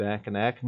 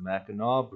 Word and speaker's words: Mackinac: Shouldn't it be Mackinac? No, it's the Mackinac Bridge. Mackinac with a W and Mackinac with a C are both pronounced the same.